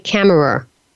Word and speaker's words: Kammerer: Kammerer. – Kammerer. – Yes? – With the two E-R's. E-R-E-R.